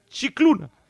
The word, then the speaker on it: Scicluna: Y esas son las directrices que nos marca Monseñor Scicluna. Mira que me cuesta pronunciarlo, Scicluna. Porque bien.